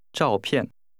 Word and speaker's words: 照片: This is pronounced with two falling tones. – 再说一次。 – 照片。